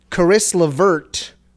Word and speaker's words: Caris LeVert: However, I will say Caris LeVert, or however you say his name.